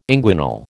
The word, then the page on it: inguinal: American English